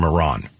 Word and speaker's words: Iran: Here's the journalist from Iran. You say you support the Iranian people, but you're pronouncing our country's name wrong our country is named Iran, not Iran.